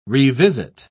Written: revisit

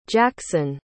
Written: jaxen

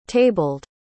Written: tabled

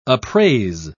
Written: appraise

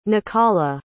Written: nacala

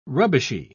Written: rubbishy

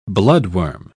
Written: bloodworm

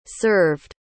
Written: served

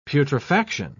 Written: putrefaction

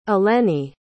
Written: eleni

